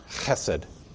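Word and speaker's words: chesed (חסד): If you're going to pronounce it properly in Hebrew, you've got to go, you know, chesed.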